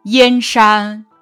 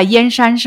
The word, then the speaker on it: 燕山: 大漠沙如雪，燕山月似钩。燕山指的是燕然山，在这里借指边塞。这是一个多音字，读燕的时候组词有燕国、燕京；读燕的时候组词有燕子、燕雀。